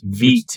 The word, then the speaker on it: Vite: That's Vite, Vite as in feet. – Vite, yeah. – Not Vite, Vite. Got a correction on that.